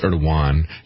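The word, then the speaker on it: Erdoğan: And I understand it's Erdogan. Yeah, he corrected me in this. I've been using the southern pronunciation here.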